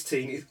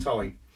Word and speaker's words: Constantine: Which everyone keeps going, it's teen. It isn't. If you look at Reed Hellblazer, he actually says it is pronounced time. So all the Americans, you were all wrong. It's time.